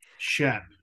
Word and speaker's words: Schoepp: Schoepp, it's okay.